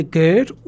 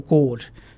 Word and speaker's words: GERD: Okay, it's either GERD or GORD. So if it's spelled esophagus with an E, then it's GERD. If esophagus is spelled as an OE, then it's GORD.